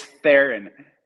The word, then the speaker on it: Theron: Did you say Therian? I take Therian as like French. – It's Theron.